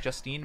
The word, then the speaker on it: Justine: So it's Justin, I-N-E. – I-N-E. – Man. – Just I-N-E. – Man. – It's Justine. – Justine. – Justin would be no E at the end.